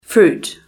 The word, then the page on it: fruit: British English